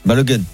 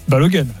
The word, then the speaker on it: Balogun: Que Balogun aux États-Unis se prononce Balogun. – Balogun, bien sûr.